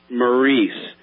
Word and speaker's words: Myrice: One kid's name, I'm thinking they pronounced it Maurice. It was spelled M-Y-R-I-C-E.